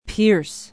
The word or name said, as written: pierce